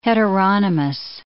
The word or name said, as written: heteronomous